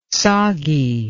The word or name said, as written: soggy